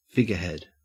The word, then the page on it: figurehead: Australian English